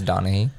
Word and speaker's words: Donaghy: Yeah, so most notably though, Tim Donaghy. I really hope that's how you say it. – It's Donaghy.